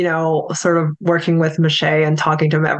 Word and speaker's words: Maciej: Cause I know you and I don't know, like I'd also like to ask about your partnership and, you know, relationship with Maciej. I'm saying the name right, correct? – Yeah, it's actually Maciej, I call him Maciej. Okay. It's just easier.